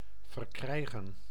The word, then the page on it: verkrijgen: [vǝɾ.kɾɛj.ɣǝː]